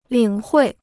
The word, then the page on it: 领会: lǐng huì